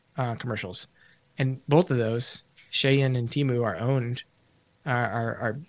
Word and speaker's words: Shein: It looks like Shine, but I'm told it's pronounced Shein.